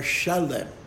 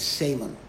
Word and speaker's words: Shalem: I mean, you could just as easily call it Shalem, which is what the Hebrew pronunciation is, but for some reason, Shalem is translated as Salem.